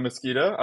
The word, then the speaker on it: Mesquita: Hello, my name is Sam Mesquita.